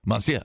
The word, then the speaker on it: monsieur: It's usually just like, they just say like, monsieur. – Monsieur? Oh, I'm putting the N. I got it. It's, that's where I'm screwing. Monsieur.